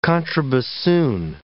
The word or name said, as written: contrabassoon